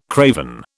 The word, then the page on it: craven: British English